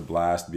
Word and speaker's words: Blxst: So I wanted to go ahead and give a shout out to Blast, BLXT. BLXST is how you spell it on all DSPs.